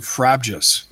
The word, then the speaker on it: frabjous: I would pronounce it frabjuice, like "Oh, Frabjous Day" from the Lewis Carroll poem.